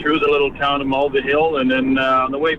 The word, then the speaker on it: Mulvihill: Well, we went to, I can spell it for you, M-U-L-V-I-H-I-L-L.